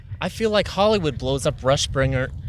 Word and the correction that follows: Rumspringa: Is that how you say it? – Rumspringa. – Room Springer. – There you go.